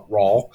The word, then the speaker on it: Rawe: Yeah, that's a good suggestion there, Mr. Joshua Rawl. Ray, how would you say it? R-A-W-E. – Raw.